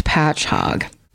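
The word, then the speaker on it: Patchogue: And in parentheses, it does say pronounced Patch Hog.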